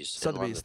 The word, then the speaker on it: Sotheby's: Sotheby's? Is that how you pronounce it? – Sotheby's, thank you.